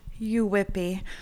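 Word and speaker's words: yuwipi: Uwipi. It's pronounced uwipi, but sure.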